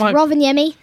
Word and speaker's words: Rovaniemi: Rovaniemi, for all those guys that don't recognise it, it's Rovaniemi.